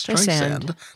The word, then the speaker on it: Streisand: She said in an interview that Tim Cook had promised her that the mispronunciation of her name is Streisand, which every Babs fan knows is Streisand. It's Streisand. – Like sand Streisand. – On the beach.